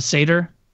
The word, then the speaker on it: satyr: Like a satire? A satyr?